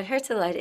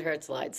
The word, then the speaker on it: Herzeleid: And the interesting thing about Herzeleid is that, I hope I'm pronouncing it right. – No, that's exactly right. – Let's just say, okay, pronouncing it Herzeleid, so that's the way it is, okay? – Yep.